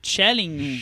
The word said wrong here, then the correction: Schlesinger: Schlesinger. – Schlesinger. Schlesinger. – John Schlesinger, aprendendo a pronúncia agora.